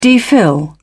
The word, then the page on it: dphil: British English